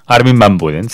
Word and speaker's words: Armin van Buuren: Bueno, el mayor especialista en música trance es, sin lugar a duda, Armin van Buuren. ¿Lo he dicho bien? – Armin van Buuren, sí.